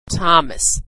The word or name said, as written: Thomas